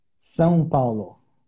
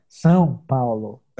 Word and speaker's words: São Paulo: How do you say it in Portuguese? – São Paulo. – Sao Paulo. Yeah, that's okay, a hard close. one. We have like this nasal kind of São Paulo.